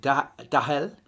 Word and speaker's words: Dale: And Jen Herrera says Dale, pronounced Dahel.